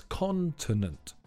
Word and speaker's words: continent: The stress is on the first syllable, so it's continent.